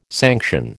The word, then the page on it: sanction: American English